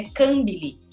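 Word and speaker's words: Kambili: com a pronúncia que ela considera correta, que é Cambili. Né, ela fala, é, o padre foi o único, né, que chama ela desse jeito.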